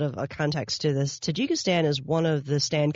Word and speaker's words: Tajikistan: Am I saying that right? – Tajikistan.